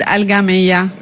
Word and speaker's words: Al-Gamiya: What is the name? – In Arabic it's called Al-Gamiya.